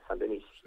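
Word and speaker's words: Saint-Denis: San Denis. No, como suena, San Denis.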